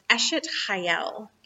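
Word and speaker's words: Eshet Chayil: And that phrase is pronounced Eshet Hayel.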